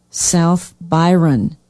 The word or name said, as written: south byron